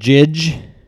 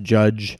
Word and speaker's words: Jydge: Yeah, so I also played what I thought was called Jidge, J-Y-D-G-E. Apparently it's still pronounced Judge.